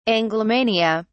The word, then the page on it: anglomania: British English